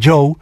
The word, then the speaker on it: Djo: D-J-O se escribe, pero se pronuncia Joe.